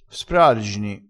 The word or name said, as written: sprargini